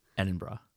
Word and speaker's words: Edinburgh: Is that not how you say it? – I don't know. How's it spelled? – E-D-I-N-B-U-G, B-U-R. Edinburgh? – Edinburgh. – But it said B-U-R-G-H. Edinburgh? What's happening?